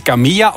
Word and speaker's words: Camilla: En hoe spreek je naam exact uit? Is het Camilla of Camilla? – Camilla.